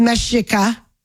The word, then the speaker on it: Mexica: Mexica, pronounced Mexica.